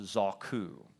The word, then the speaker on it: zaku: And that word innocent is a Hebrew word that is pronounced zaku.